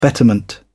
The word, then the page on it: betterment: British English